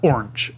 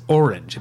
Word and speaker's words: orange: but I had to teach myself that the color / fruit orange has two syllables in it. It is not orange or arch. – Orange. Yeah. – I think the same thing was him would say orange. – Orange. – But it's orange. It's two syllables and it's an O-R.